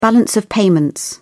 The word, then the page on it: balance of payments: British English